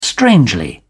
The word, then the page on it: strangely: British English